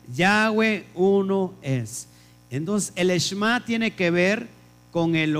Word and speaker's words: Shema: Lo que tú ves en pantalla, bueno, se dice Shema, pero en realidad se pronuncia Shema.